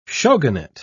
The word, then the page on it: shogunate: British English